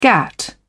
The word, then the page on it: gatt: British English